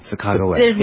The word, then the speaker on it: Sacagawea: Sacagawea.